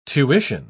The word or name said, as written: tuition